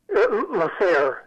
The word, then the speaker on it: La Serre: You mentioned the restaurant La Serre. Did I pronounce it correctly? – La Serre, yeah.